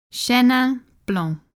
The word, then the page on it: Chenin Blanc: French